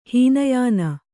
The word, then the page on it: ಹೀನಯಾನ: hīna yāna